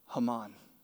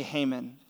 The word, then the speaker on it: Haman: I say, how do you say this? And he goes, Haman. And I was like, yes. But then he goes, but you Americans say Haman.